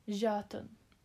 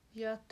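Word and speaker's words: Jötunn: Den kallas för, se om jag uttalar rätt, men Jötun. Och det är från nordisk mytologi. – Säg det igen. – Jötun. – Jötun. – Det stavas så här. J-Ö-T-U-N-N.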